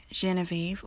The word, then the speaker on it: Genevieve: Felix, Frederick, Genevieve, also with a soft G.